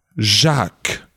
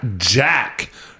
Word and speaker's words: Jacques: It's pronounced Jacques. Jacques. Not Jack. Jacques. Jack lacks smacks. You know? Jacques there's almost two syllables. It's like attack. Jacques. And there's a k at the end.